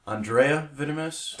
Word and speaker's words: Andrieh Vitimus: we're sitting here with, I want to get this pronunciation right, Andrea Vitimus.